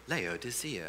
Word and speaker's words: Laodicea: You're like, how do you pronounce Laodicea? And it's like, Laodicea.